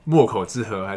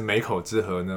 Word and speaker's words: 沒口之河: 我就说，就是，呃，因为这本书叫《墨口之河》还是《美口之河》呢？就会从这边怎么念？ – 我我通常都会说都可以了，但是墨口河是一个我发想的起点，就是东台湾的东部南部就会有一些河，冬天就不会流到海里，会断头在沙滩上。